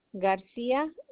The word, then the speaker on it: Garcia: Luisa LUISA Garcia GARCIA.